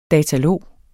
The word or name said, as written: datalog